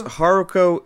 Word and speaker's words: Haruko: Because Haruko, am I saying her name correctly? – Yeah, it's Haruko.